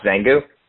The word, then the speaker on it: Zangu: So in going back to is it pronounced Zangu? – Yeah. – Yeah, so going back to Zangu, I mean, 1999, like, how are orders being placed over the phone?